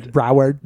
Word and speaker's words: Broward: Broward.